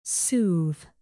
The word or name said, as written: soothe